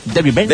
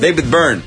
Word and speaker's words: David Byrne: David Byrne. David Byrne.